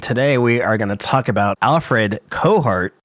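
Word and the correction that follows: Cortot: Courteau, that's what it is. – I looked it up. – Yeah. – He's French. They don't pronounce like a half. – Yeah, that's right, Courteau.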